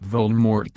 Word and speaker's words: Voldemort: The name Voldemort comes from the French words meaning fly from death. The T at the end of Voldemort is silent.